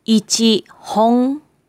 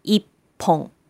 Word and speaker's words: 一本: It is difficult to pronounce ichi hong or ichi bong. So we say ippon.